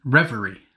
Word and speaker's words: reverie: Dunley disturbed her revelry. What is that? – Reverie. – Oh, I said it right the first time? – No.